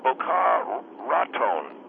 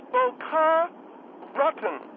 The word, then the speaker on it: Boca Raton: He was saying that it's not Boca Raton, it's Bocaw Raton. – No, you... Oh, God, did you butcher that alive. No, It's Boca Rotten. – Like rotten? – No, not rotten. Not rotten. No, it isn't that. No, I understand what are you saying it's Boca Rotten as the rounded... There's a rounded N?